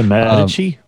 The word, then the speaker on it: Medici: So like essentially it was the Queen of France, Catherine de Medici. Butchered that, whatever. – It's a Medici. – Medici, thank you.